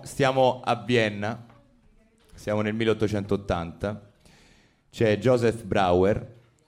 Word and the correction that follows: Breuer: penso si, pronunci, si pronunci così. Breuer, Breuer, ecco, vedi, Breuer, vabbè, perdonatemi, è tedesco.